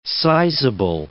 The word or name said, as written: sizeable